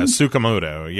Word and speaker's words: Tsukamoto: I am terrible at pronouncing the name, so I will probably butcher this, but it's Shinya Tsukamoto or something. – Yeah, Tsukamoto. Yeah, yeah.